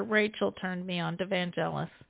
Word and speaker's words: Vangelis: Oh, I love Vangelis. – Is that how it's pronounced? Okay. – Yeah, Vangelis.